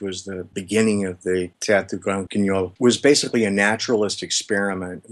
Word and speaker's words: Théâtre du Grand Guignol: I'd love to talk more about art and creative endeavors, maybe through talking about your article about the Théâtre du Grand Guignol.